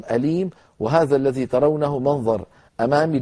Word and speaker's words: أليم: عذاب أليم، نحذف التنوين ونحذف الحركة ونخب السكون ثم نضم الشفتين من غير صوت.